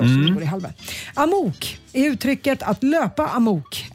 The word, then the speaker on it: amok: Och uttalas tydligen amok, om det ska vara.